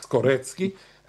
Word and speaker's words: Skorecki: Karl Skorecki, and he pronounces the C's as C. It's not like Moroccan, which is Mrocki, or it's not like Slaki, which is Slutski. He is a real Skorecki, and he is an expert of that.